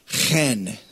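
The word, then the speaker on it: chen (חֵן): The Hebrew word is chen. It said you're supposed to be some spit coming out of your mouth when you say it, but it's spelled H-E-N, but it's a hard H, hen.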